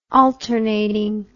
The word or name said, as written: alternating